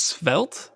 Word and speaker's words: Svelte: So I cannot pronounce it to save my life, but it's S-V-E-L-T-E. And I think it's Svelte. That's what I've heard everybody say is Svelte. It's like felt, but like, I want to say like skin.